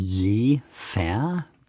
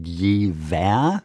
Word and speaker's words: Jever: Das war Ihnen je fair? Durch Sie habe ich gelernt, dass es nicht je wer heißt. – Ja, in der Werbung wird das immer falsch ausgesprochen.